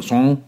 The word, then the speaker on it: sans: Sans Skydivers for the Advancement of Naked Skydiving, SANS, udtales, udtaler man det på fransk, så son, cirka, og så øh, betyder det uden.